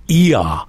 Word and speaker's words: Oia: We stayed in Ia. Now, that's spelled O-I-A.